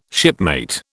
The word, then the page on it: shipmate: British English